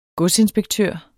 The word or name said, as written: godsinspektør